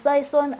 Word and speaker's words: Sison: Mummy, Stella, Susan, Barbara, who's also called Sison, and Sheila. The S rhymes with hiss.